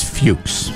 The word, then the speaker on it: Fuchs: It's Fuchs. – I believe it's Fuchs. – That's what people who have that name pronounce it as.